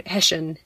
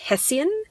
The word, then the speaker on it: Hessian: to me, that fabric has always been called Hessian. And again, horrified looks, because apparently here it's pronounced Hessian.